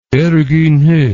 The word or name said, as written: Ergun He